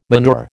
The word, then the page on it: manure: American English